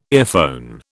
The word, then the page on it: earphone: British English